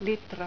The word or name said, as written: litra